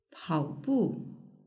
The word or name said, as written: paobu